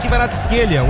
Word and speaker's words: Kvaratskhelia: Gol de Desiré Doué, Kvitch Kvaratskhelia. O Aston Villa descontou com o Brian Maggio, nasceu em 2009. – Gostei da pronúncia aí do Kvaratskhelia.